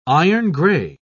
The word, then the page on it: iron-grey: British English